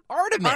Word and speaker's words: Artemis: Uh, so how do you pronounce it? – Artemis. Artemis. Artemis.